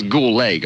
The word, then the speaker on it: Goulet: Here's Mr. Bob Goulet. His name always looked like it should be Robert Goulet, but it's Goulet because you don't say the D.